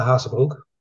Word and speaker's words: Hazebroek: It's Wouter Hazebroek.